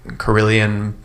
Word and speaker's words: carillon: He's a carillon player.